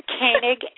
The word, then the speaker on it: Koenig: Is it Koenig? – I get asked that a lot. It's Koenig as if there's a long A.